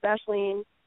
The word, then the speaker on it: Aisling: A-I-S-L-I-N-G. It sounds like, or it looks like Aisling, but it's pronounced Ashling, and my last name is a little bit easier, Peartree, like a tree that grows pears, P-E-A-R-T-R-E-E.